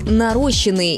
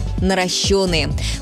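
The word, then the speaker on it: наращённые: Часто можно услышать «нарощенные» или «наращенные». Правильный вариант один – «наращенные». Ударение всегда падает на «ё».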